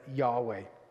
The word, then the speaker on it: Yahweh: And so when our Bible was translated in the Authorized Version, they would render it as Jehovah. We would probably say it was better pronounced as Yahweh.